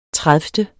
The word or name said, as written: tredivte